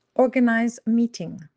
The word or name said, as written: organise meeting